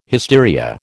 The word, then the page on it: hysteria: American English